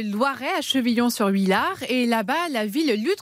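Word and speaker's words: Chevillon-sur-Huillard: Euh, comment vous prononcez exactement le nom de votre ville Chevillon, Chevillon sur Huillard.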